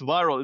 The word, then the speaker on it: Vyrill: And that's spelled V-Y-R-I-L-L. – Correct. Viral. So videos go viral. It's just a tweak on the word viral, right?